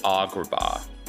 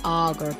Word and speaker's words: Agrabah: I wish I knew the word for the ah vowel sound, but I would say Agrabah. – Agrabah. – Agrabah. Like it's all ah, ah, ah.